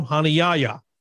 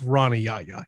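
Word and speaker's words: Rani Yahya: They played the audio file for how we have always called him, Honey Yaya. He calls himself Ronnie Yaya. He has Americanized his name.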